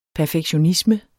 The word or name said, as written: perfektionisme